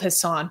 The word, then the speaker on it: Hassan: It's spelled Hassan, but it's pronounced Hassan.